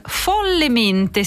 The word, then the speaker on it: Follemente: 10 anni fa c'era Perfetti Sconosciuti, lo scorso anno Paolo Genovese ci ha, tra virgolette, riprovato con Follemente. Si scrive tutto attaccato, ma l'accento è sulla prima lettera, folle e mente naturalmente.